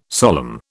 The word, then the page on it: solemn: British English